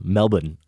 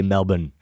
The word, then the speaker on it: Melbourne: And speaking of Melbourne, Australia, Mike sent us a lovely note and was very impressed with the fact that we pronounced it properly and not Melbourne like most Americans would.